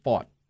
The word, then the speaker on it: Pfaadt: And his name is spelled P-F-A-A-D-T, but it's pronounced Pfaadt. – Pfaadt. – Like fought the war.